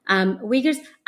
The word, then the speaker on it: Uyghur: Yeah, I'm really interested in your methodology because there's, you know, you occasionally talk about ethnographic research, having interviewed Uyghurs.